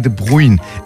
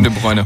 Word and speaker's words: De Bruyne: Ich habe den Namen, glaube ich, schon falsch ausgesprochen. – Ja. – Und ich glaube sogar beide Namen falsch ausgesprochen. – Nein. – Erling Haaland war noch richtig, ne? – Ja. – Kevin De Bruyne heißt es, glaube ich.